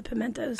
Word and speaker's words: Pimentos: She did her first folk fest, started singing with Lunch Meat and Pimientos. Is that the right pronunciation in Spanish? – Yeah, it's the Haynes version of Pimientos. – Okay. – It's the Pimentos. – Oh, the Pimentos. Okay.